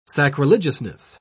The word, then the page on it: sacrilegiousness: British English